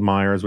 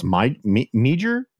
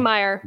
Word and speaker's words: Meijer: And yet I've listened to a bunch of podcasts and cable shows, and people are like, I think it's pronounced Meijer? – Oh, God. No. Definitely Meyer.